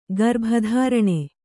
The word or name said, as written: ಗರ್ಭಧಾರಣೆ